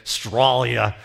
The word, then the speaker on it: Australia: You leave the A and the U off, and you just say, Stralia.